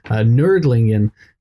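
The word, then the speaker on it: Nördlingen: It comes from a town in Germany called Nordlingen, where I think the Germans pronounce it, to my dismay, Nerdlingen, which would make my name pronounced nerdy, which is not what I was hoping for.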